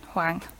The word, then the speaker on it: Hwang: I was going to say, that's spelled H-W-A-N-G. – Yes, Huang.